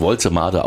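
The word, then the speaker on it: Woltemade: Waltermade oder so, aber Waltermade. Er wird wirklich Waltermade ausgesprochen.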